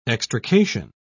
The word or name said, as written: extrication